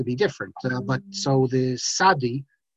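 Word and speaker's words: Sadi (צדי): And just like if you look at the Sephardim today, you look at the Syrians, when they write Matzah, they write M-A-S-A-H, sometimes with a dot under the S. Or, and that's because, you know, this T-Z we have, we pronounced it as Sadi, That's an Ashkenazic mispronunciation. Every group has mispronunciations, and we have a few as well. And there is no TZ in classical Hebrew. That's an Ashkenazic thing for a few hundred years.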